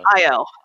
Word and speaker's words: Ayo: I think it's just Io or Io. Okay. – Yeah. A Y O. – Yes, Io, and she demands Zemo, and she demands it now.